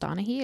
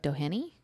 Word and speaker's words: Doheny: But I'm pretty sure it's not pronounced Donaghy. It looks like Doheny.